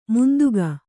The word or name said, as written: ಮಂಥಿಕ